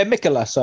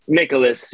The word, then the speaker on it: Mikolas: They're smashing the ball, and I think they'll be able to put a couple runs on the other pitcher, Mikolas. Is that how you pronounce it? Mikolas. – Mikolas, I go for. – Mikolas, yeah.